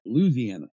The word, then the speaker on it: Louisiana: You got to say it right. Louisiana.